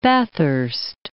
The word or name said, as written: Bathurst